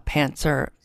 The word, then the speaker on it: pantser: And I know a lot of writers say, well, I'm a pantser. I always say it wrong. I always say pantser without the T.